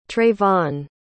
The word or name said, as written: treyvon